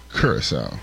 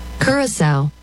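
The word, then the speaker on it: Curaçao: Curacao. – Okay, Curacao. There you go. Ha! It's not very often that happens. All right. When we get back, we'll get... – The locals pronounce it Curacao.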